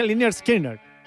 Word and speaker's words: Lynyrd Skynyrd: Mira, se pronuncia Lynyrd Skynyrd. Así, tal cual. Está escrito y uno tiene que leerlo. Dice pronounced Lynyrd Skynyrd, con una adaptación que tampoco es como escritura fonética, pero intenta emular una escritura fonética